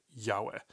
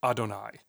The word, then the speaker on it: YHWH: Because when we're reading Hebrew and we come across this gibberish word Yahweh, we don't actually pronounce it Yahweh. A lot of Jews might not be familiar with the term. And that's because when Jews look at those letters, the YHWH, we pronounce it as Adonai.